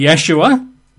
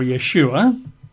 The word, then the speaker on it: Yeshua: Among them would be Yeshua or Yeshua. That's Y-E-S-H-U-A, or sometimes Y-apostrophe, S-H-U-A, Yeshua. Or, of course, it could be Yahshua, Y-A-H-S-H-U-A, Yahshua.